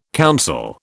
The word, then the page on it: council: British English